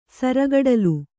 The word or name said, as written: ಸರಗಡಲು